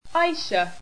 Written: Aisha

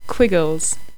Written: Quiggles

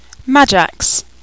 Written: Magax

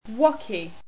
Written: wocky

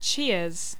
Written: chias

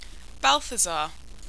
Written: balthazar

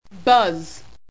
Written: buzz

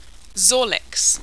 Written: zorlix

